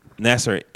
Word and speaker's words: Nassar: Nassar.